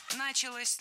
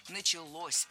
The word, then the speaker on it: началось: И вот именно в тот момент в Голландии, если я правильно понимаю, началось развитие вот именно... – Началось неверно. Вот здесь уже ударным становится окончание. Началось. Дело в том, что возвратная часть «се» во многих словах как бы притягивает ударение к себе.